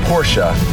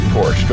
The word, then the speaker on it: Porsche: Hey, PK and DJ, it's pronounced Porsche, not Porsche. Go down to the Porsche dealership and they will correct you on that. The Germans pronounce their car as Porsche.